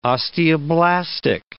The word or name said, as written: osteoblastic